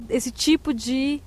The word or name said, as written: esse tipo de